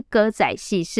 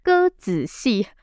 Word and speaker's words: 歌仔戏: 而且看戏就是当时台湾人最主要的娱乐活动，什么歌仔戏呀、布袋戏哦，不是歌仔戏，是歌子戏。身为一名专业的家教老师，这个字的读音可不能错，是歌子戏，不是歌仔戏哦。